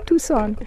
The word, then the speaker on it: Tucson: Tucson, comme on dit en France. – Ouais. Ah, tout le monde dit ça, mais c'est sicilien. Alors, s'il vous plaît, quand vous venez ici, il faut prononcer Tucson.